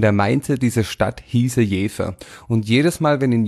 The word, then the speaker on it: Jever: Das heißt eigentlich nicht Jever, das heißt Jever, aber du mit deiner komischen schwäbischen Aussprache, du darfst von mir aus auch Jever sagen. Stimmt gar nicht, sage ich da. Dieses Bier heißt tatsächlich und im Ernst Jever, weiß aber niemand.